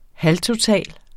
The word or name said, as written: halvtotal